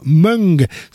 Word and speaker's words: Mwng: Eh bien, voilà, c'est comme ça que ça s'écrit, M-W-N-G, ça se prononce Mung.